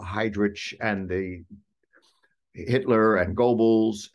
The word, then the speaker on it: Goebbels: So would you pronounce it correctly for me once? – Joseph Goebbels. – Joseph Goebbels. – That's right.